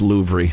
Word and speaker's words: Louvre: Louvre and look at the paintings, it'd be as good as being there. – I'm pretty sure it's pronounced Louvre.